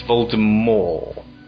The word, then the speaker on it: Voldemort: It's odd that at no point in the filming would she sort of step in and go, hang on, it's Voldemort. You're saying it wrong. All of you are.